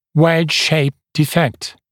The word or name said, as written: wedge-shaped defect